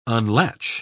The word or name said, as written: unlatch